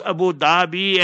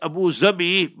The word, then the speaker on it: Abu Dhabi: This Abu Dhabi and MBS and them is Abu Zabi. But if I say Abu Zabi, you won't understand. That's the proper pronunciation.